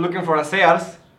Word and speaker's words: Sears: Aquí es muy común, güey, que el mexicano le diga Sears. – Sears, sí. Pero en Estados Unidos no, güey, la pronunciación de, las, de la A y la A allá es muy diferente. – Entonces allá es como Sears. Sears.